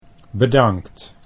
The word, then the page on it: bedankt: Dutch